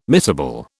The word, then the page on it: miscible: British English